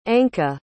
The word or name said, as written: Anca